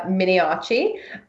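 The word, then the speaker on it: Miniaci: I am also on Twitter, where my name is just my name, which is Sarah Miniacci. Miniacci does not have an H, despite the pronunciation. It's M-I-N-I-A-C-I.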